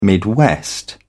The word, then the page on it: midwest: British English